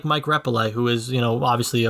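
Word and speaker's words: Repole: I mean, and it's pronounced Mike Repele.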